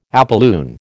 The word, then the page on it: apolune: American English